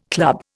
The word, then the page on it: club: Indian